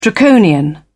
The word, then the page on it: draconian: British English